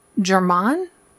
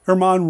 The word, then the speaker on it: Germán: By the way, the late German? German? – Uh, well, it's, it's, Spanish. How would you say it in Spanish? Not like that. Herman Robles, I believe.